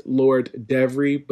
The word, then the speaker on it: Lord Devery: That is _LordEvery. It is actually pronounced Lord Devery, but it is spelled Lord Every.